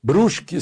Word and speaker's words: Brusque: Aliás, por falar em eleição, Brusque, Santa Catarina, eu estou pronunciando como se pronuncia lá, né?